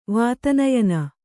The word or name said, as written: ವಾತನಯನ